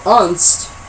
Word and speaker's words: Unst: With its frothing seas, soaring cliffs, and grassy plains, it's easy to see how Unst is basically UNST, that's how it's said, could fire up the imagination.